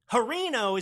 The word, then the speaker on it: Hirono: Hirono is not a mispronunciation. It's Hirono.